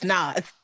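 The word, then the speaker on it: Nas: They was like, it's Naz.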